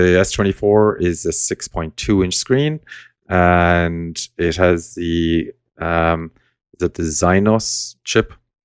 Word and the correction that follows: Exynos: Exynos, I think, isn't it? – All right. Yeah. Exynos. Yeah. Whatever it's pronounced anyway.